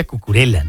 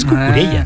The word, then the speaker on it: Cucurella: Cucurella, que hay quien lo pronuncia Cucurella, ¿no? Como así, como italiano, Cucurella. Pero es Cucurella, ¿no? – Cucurella.